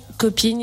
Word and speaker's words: Kopin: Alors, littéralement, Cop-In, il s'écrit K-O-P-I-N, parce que depuis plusieurs années, le K vient symboliser le cancer.